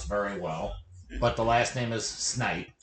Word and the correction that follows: Snape: And Snape. – Snape. With the last name of Snape, which I obviously cannot pronounce now.